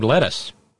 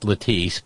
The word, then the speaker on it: Lettice: And when Dudley finally remarried, he remarried a woman named Lettuce. He waited. – A woman named who? – Lettuce. – Lettuce. – Lettice. Lettuce. Lettuce Nollies. Probably pronounced Lettice, but it looks like lettuce on the page.